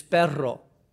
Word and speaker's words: perro: He would say, "How do you say, see, the word dog in Spanish is perro. And you have to roll your R's.